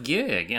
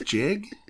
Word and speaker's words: gig: Because it's a tough gig, and I think every now and then you got to take a lemon. – Did you say a tough jig? – Gig.